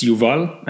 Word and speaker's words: Yuval: Yes, it's Yuval, and I'm happy to be here.